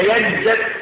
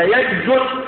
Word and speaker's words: ينجس: نرجع بقى عشان ما نطولش، قوله لا ينجس فيه لغتان، ضم الجيم وفتحها، يعني لو قلت المسلم لا ينجس فهي لغه فصيحه، وان قلت المؤمن او المسلم لا ينجس بضم الجيم، فتح الجيم او ضم الجيم مش علم نحو، ده علم ايه؟ صرف. الكلمه كده تنطق كده في لغه العرب، ينجس وينجس بالتصريفين.